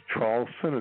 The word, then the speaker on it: Traulsen: The last name is Traulsen.